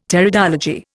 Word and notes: Pteridology: Indian

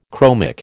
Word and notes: chromic: American English

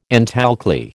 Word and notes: antalkali: American English

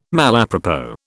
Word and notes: malapropos: British English

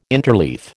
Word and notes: interleaf: American English

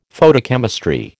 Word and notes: Photochemistry: American English

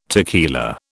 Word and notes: tequila: British English

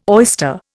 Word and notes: oyster: Indian

